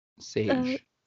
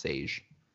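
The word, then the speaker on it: Šejić: Sage. – It's spelled Sejic, but I've heard it pronounced Sage.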